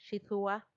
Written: xidhua

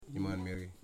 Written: Imanmeri

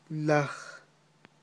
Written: laj

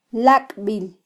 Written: lakbil

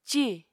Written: cì

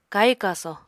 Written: kahi-kaso

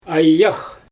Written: ajax

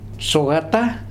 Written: Soata

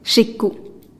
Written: xiku

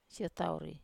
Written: siotauri

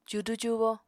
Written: cudu-cubo

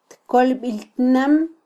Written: tkolbiltnam

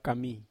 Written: kami

